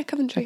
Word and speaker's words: Coventry: Coventry. Am I saying that properly? – Yeah, Coventry.